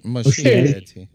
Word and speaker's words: Muschietti: Muschietti.